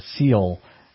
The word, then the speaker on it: Ciel: They're just teasing us, but it's called the Seal, It's spelled C-I-E-L, the SEAL concept.